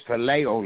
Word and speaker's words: phileo: And then we have, and I'm going to try to pronounce this right, philia, P-H-I-L-I-A. And this love is the type. – It's phileo love. – Phileo love. Thank you, Pastor.